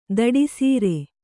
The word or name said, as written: ದಿಟ್ಟಿಸರ